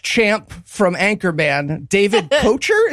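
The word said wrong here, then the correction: Koechner: is that how that name's pronounced? – Ketchner.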